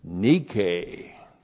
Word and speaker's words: nike: And then we have nike. N-I-K long E.